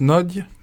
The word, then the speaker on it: Nagy: Ça se prononce Nodge, mais Nagy, oui, pour les Français on dit.